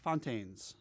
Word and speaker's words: Fontaines: Fontaines.